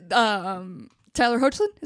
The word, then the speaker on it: Tyler Hoechlin: And Tyler Hoechlin? Is that how you say his name? – Yeah.